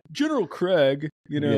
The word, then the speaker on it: Krieg: But General Krieg, and I would have not pronounced it Krieg. I would have pronounced it Craig based on how it's spelled.